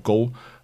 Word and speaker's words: GHO: Das heißt, äh, Go heißt der, glaube ich. Also G-H-O wird der geschrieben, aber ich glaube, Go ausgesprochen.